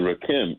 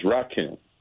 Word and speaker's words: Rakim: You said Rakim. It's Rakim. – Rakim. Sorry. My bad. I was thinking about the line more than I was thinking about Eric B. and Rakim.